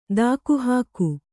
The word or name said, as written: ದಾಕುಹಾಕು